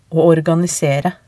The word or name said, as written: å organisere